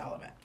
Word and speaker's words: Olivet: After a blowout exhibition game against Olvia College. – Olivet. – Potato, potato. – Not quite. Not even close, trust me. Olivet recruited me to play college football, trust me. It's pronounced Olivet.